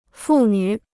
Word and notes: 父女: fù nǚ

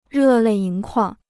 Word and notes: 热泪盈眶: rè lèi yíng kuàng

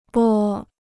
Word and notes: 拨: bō

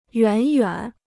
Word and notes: 远远: yuǎn yuǎn

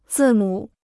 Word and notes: 字母: zì mǔ

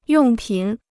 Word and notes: 用品: yòng pǐn

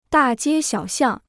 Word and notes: 大街小巷: dà jiē xiǎo xiàng

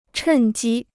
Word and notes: 趁机: chèn jī

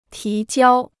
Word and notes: 提交: tí jiāo

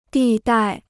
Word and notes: 地带: dì dài

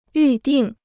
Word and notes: 预定: yù dìng

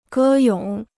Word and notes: 歌咏: gē yǒng

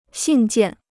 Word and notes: 信件: xìn jiàn

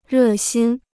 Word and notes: 热心: rè xīn

